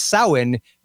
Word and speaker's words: Samhain: The Clive Barker magazine, it would be pronounced Samhain, because Samhain is woefully incorrect. It's Gaelic. It's Samhain, which is a festival that takes place.